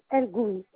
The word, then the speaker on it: Elgouy: c'est Elgouy, EL. Espace Gouy Fatima, comme ça se prononce.